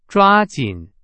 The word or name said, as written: 抓紧